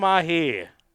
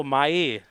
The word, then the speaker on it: Mahé: Hallo Schmiso, beim H müssen wir noch arbeiten. In Frankreich wird das ja nicht ausgesprochen. – Ach so, Mahé dann quasi.